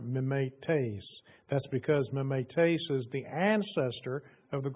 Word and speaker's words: mimetes: It is the word mimetes, or actually, if I put the accent, if I pronounce it properly, it would be mimetes, spelled M-I-M-E-T-E-S in English.